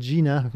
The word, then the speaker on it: Jena: You would pronounce it Jena. We say Jena.